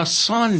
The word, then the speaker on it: Sanpei: Do you like Sanpei or Sanpei? No, Sanpei. – Sanpei. Sanpei is it sounds, the it sounds French. Sanpei.